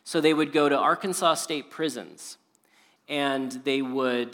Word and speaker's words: Arkansas: But in Arkansas, or Arkansas, if you want to pronounce it how it's spelled.